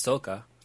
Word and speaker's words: Soka: We'll you know, Sokka, actually, if you pronounce it correctly, it's Soka, which is Japanese for...